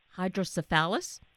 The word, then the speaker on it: hydrocephalus: And for yourself, as a child having to go through dealing with the hydrocephalus, that's the correct pronunciation, right? – Yes.